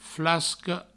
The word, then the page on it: flasco: flascou ou flasco; Provençal